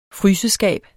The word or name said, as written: fryseskab